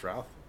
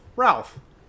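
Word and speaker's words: Routh: I think it's pronounced Ralph. Roth? – Ralph. No, it's, Ra- it's Ralph. – It's Ralph. It's like mouth. Ralph. – Ralph, like mouth.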